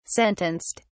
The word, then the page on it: sentenced: British English